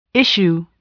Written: issue

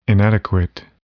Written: inadequate

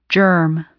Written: germ

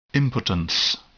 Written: impotence

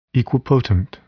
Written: equipotent

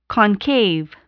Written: concave

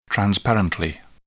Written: transparently

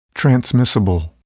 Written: transmissible

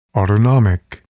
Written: autonomic

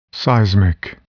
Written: seismic

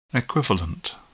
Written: equivalent

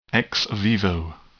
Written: ex vivo

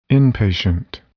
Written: inpatient

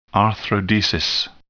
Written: arthrodesis

